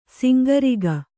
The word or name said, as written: ಸೊಂಕುರೋಗ